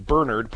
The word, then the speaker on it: Bernard: Well, I got into the habit of saying Bernard, putting the accent on the first syllable, because if you say Bernard, so many people immediately think of the dog rather than the church history behind it.